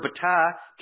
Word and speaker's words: batah: If you would like to put this in your notes, it's spelled B-A-T-A-H, B-A-T-A-H, batah. And here's what the theological word book says about this word trust coming from the Hebrew word batah.